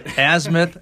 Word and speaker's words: Azimuth: Uh, Azimuth, I said, I said it wrong again. – Azimuth, Azimuth dot Yeah.